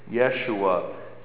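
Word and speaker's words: Yeshua (ישוע): But Yeshua is the more, a little closer to the way the Hebrews, the Israeli people would pronounce it. The Israelites would have pronounced it, Yeshua.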